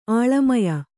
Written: ಆಳಮಯ

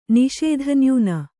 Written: ನಿಷೇಧನ್ಯೂನ

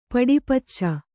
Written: ಪಡಿಪುಚ್ಚ

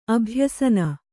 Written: ಅಭ್ಯಸನ

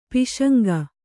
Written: ಪಾಸಂಗ

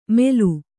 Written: ಮೆಲು

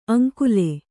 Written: ಆಂಗುಲಿ

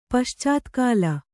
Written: ಪಶ್ಚಾತ್ಕಾಲ

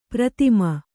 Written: ಪ್ರಥಮೆ

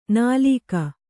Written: ನಿಲುಕು

